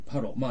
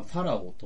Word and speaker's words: ファラオ: あの、出エジプト時に出てくるパロ。まあ今、ファラオともね、あの、発音されますけど、いわゆるそのエジプトの王ですよ。